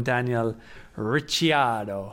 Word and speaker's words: Ricciardo: Now the problem is how I was pronouncing it was also wrong. So I'm now going to have to call him Daniel Ricciardo. And you have to say it like that as well.